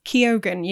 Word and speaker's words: Keoghan: Keoghan, yeah. – Question mark? – No, that's, well, I looked it up. That is apparently the correct way to pronounce it.